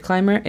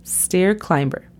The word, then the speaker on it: climber: La pronunciación fonética de stair climber es S-T-E-R, stair, y climber es C-L-A-I-M-B-R. Nota cómo no tenemos vocal entre la B y la R. Entonces, escucha la pronunciación. Stair climber. Stair climber.